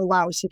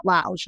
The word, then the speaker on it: Laos: Laos, 90% of the girls we pull out of the Thailand brothels come from Laos, or Laos, if you want to pronounce the S, but the locals say it Laos, and that's how I say it.